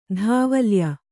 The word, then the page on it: ಧಾವಲ್ಯ: Kannada